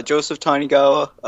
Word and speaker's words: Tanigawa: On Facebook and Twitter, Joseph Tanigawa.